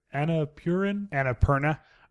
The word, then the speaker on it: Annapurna: Annapurna picture.